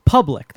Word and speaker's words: Publique: Publique. – Say the third one. – Congo. – Public. That's how you say it. Public. – Yeah, but it's like Belgian French.